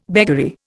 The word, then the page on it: beggary: Indian